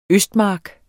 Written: østmark

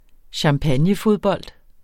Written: champagnefodbold

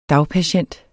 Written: dagpatient

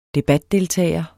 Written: debatdeltager

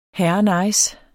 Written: herrenice

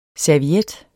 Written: serviet

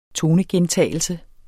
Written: tonegentagelse

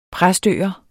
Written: præstøer

